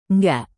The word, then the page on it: nggak: Indonesian